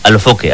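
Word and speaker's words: Alofoke: ¿Pero se llama Foker o Fokel? – Alofoque. – Alofoque, así Alofoque. Como suena.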